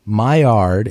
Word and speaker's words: Maillard: So Maillard, and that's spelled M-A-I-L-L-A-R-D, Maillard reaction is responsible for a lot of fantastic flavors that we are used to in cooked food.